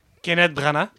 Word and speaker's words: Kenneth Branagh: Kenneth Branagh. Merci. C'est ça, je l'avais bien prononcé.